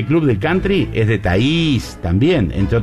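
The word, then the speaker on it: Thays: Porque la gente dice Thaïs. No. Mónica Caen Danvers, parienta de Thaïs, nos decía, así se pronuncia, Thaïs.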